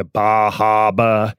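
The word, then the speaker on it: Bar Harbor: We went to Bahaba, as they pronounce it up there.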